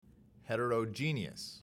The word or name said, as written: heterogeneous